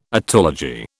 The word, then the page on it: otology: British English